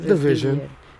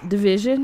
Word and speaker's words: DVSN: Do he say his name a certain way? Or Division. – Division? – I think it's Division. – DVSN. Division.